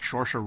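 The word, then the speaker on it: Saoirse: Shorsha Ronan. Who who's that? the girl from Brooklyn. Oh, that's how you pronounce her name? Yeah. Yeah. Oh.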